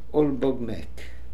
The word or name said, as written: Olbogmek